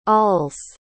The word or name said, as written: awls